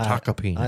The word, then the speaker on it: Tacopina: Tacopina. – Peanut. – That's, it is spelled with an M, but it's taca is the pronunciation. Taca peanut.